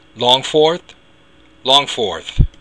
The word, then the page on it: long-phort: Irish